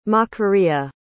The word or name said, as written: macaria